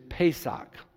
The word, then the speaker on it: Pesach: It's pronounced in Hebrew, Pesach.